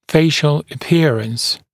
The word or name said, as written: facial appearance